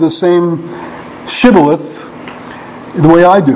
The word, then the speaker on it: shibboleth: You remember the battles in the book of Judges where the sign of whether someone was Benjaminite, I think it was whether they could say Shibboleth or Sibboleth. I mean, it's basically the same word, but they pronounce it differently.